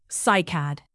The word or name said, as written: cycad